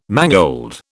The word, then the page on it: mangold: British English